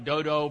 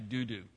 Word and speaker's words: Dudu: And then after him, the next guy is Eleazar, the son of, it looks like Dodo, but actually that's not how you pronounce it. So I want to set y'all's mind at ease. He's not the son of Dodo. You don't pronounce it that way in Hebrew. It's actually Dudu.